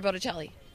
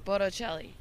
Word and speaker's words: Bocelli: Wait, I think you said his name wrong. Wait, how do you say it? – Andrea Bocelli. – How are you saying the last name? – Bocelli. – Bocelli? It's that? – Yeah.